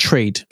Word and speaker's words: Treyd: So you're spelled T R E Y D, right? But you're pronounced trade.